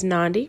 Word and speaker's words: Nandi: My name is Nandi, rhymes with Gandhi.